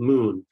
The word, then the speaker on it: MXXN: First off, I'll chat to Darnell Smith of Moon, which is spelled M-X-X-N.